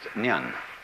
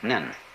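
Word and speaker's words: nyun (念): It's pronounced nyun. In Japanese, nin.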